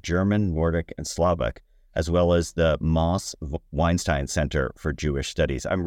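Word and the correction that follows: Mosse Weinstein: It's counterintuitive. It's Mossy Weinstein. – Okay, Mossy Weinstein.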